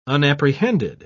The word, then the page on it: unapprehended: British English